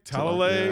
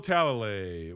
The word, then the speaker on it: Talalay: Tadalay? Is it? – Talalay, yeah, okay, yeah. Rachel Talalay. It was produced by Robert Shea with a screenplay by Michael DeLuca with a story by Rachel Talalay.